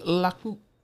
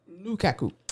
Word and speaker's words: Lukaku: Romelu Laku. Lukaku.